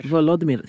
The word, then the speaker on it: Volodymyr: Volodymyr.